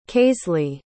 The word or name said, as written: kaislee